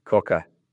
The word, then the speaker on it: Quokka: And I might not pronounce this right, but is it Quokka? – Yes, Quokka. Yeah.